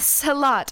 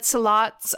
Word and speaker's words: Sehlat: A Salat, I think is how you pronounce it. S-E-H-L-A-T. Mm.